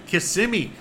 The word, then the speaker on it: Kissimmee: Kissimmee. That's how you say it right.